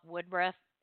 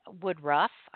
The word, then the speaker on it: Woodruff: We also had Bob Woodruff, who is Woodruff. I'm sorry, I mispronounced that.